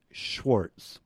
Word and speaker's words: Schwartz: Schwartz. You have to spell that the Jewish way. It's S-C-H-W-A-R-T-Z.